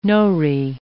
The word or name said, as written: nori